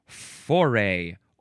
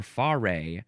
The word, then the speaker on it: foray: Next is foray or foray or also foray or foray. You can emphasize either syllable, I guess. F-O-R-A-Y.